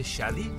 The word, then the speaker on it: Shadi: Hola, mi nombre es Shadi Valenzuela. – ¿Cómo se escribe tu nombre? – S-H-A-D-I. – Entonces se escribe como Shady, pero se dice Shadi.